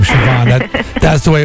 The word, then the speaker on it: Siobhan: Said, Siobhan is spelled C-S-I-O-B-H-A-N. It's of Irish ancestry, like the name Sean, which you think would be spelled differently, but it's spelled S-E-A-N. So I now know it's Siobhan.